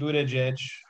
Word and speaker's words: Buttigieg: I mean, yeah, Pete Buttigieg.